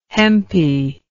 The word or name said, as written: hempy